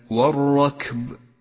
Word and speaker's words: والركب: اقرأ كلمة والركب والركب، ولاحظ أن اللام شمسية تكتب ولا تلفظ، ولذلك ميزها لك مصحف التجويد باللون الرمادي إشارة منه إلى عدم نطقها.